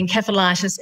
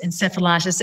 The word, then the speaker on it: encephalitis: so, and it can be pronounced absolutely encephalitis, encephalitis. It's it's just it's accepted both ways it's an inflammation of the brain.